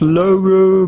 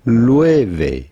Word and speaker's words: Loewe: It's not Loro, but rather Loewe.